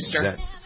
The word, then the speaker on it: Feimster: Feimster.